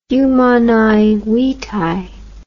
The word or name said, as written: Humanae Vitae